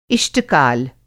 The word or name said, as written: इष्ट-काल